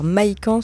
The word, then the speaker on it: Mahikan: Alors, Maikan, ça s'écrit M-A-H-I-K-A-N. – Je l'aurais pas écrit comme ça.